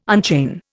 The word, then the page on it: unchain: Indian